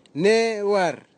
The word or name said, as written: newar